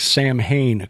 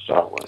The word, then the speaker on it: Samhain: And now, Samhain is the word that, if we're looking at it through our American eyes, looks like Samhain, correct? – That's correct. It looks like Samhain, but the Celtic pronunciation is Samhain.